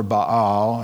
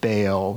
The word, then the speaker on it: Baal: He set up an altar for Baal, and a lot of people pronounce it Baal, but it should be Baal, in the temple of Baal that he built in Samaria.